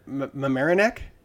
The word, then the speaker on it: Mamaroneck: I actually don't know how to say the town name. – No, I don't. – It's Mamaroneck. – Mamaroneck.